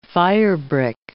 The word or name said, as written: firebrick